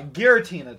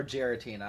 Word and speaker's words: Giratina: Also, is it pronounced Giratina or Giratina? I never I think it's Giratina.